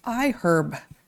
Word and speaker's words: iHerb: It's iHerb. That's I-H-E-R-B. And yes, that is how it's pronounced, even though the word herb is not pronounced with the audible H.